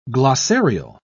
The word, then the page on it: glossarial: British English